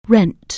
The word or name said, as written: rent